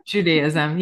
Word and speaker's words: Judaism: Judaism.